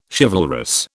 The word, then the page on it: chivalrous: British English